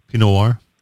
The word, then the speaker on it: Pinot Noir: Is that the right pronunciation? Pinot Noir? – Yes.